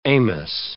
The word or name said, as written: Amos